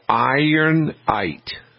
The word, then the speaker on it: Ironite: There is a product called Ironite, and it's spelled I-R-O-N-I-T-E. Ironite. – I-T-E. – Yep. Yes. Okay. I-R-O-N-I-T-E.